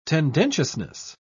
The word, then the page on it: tendentiousness: British English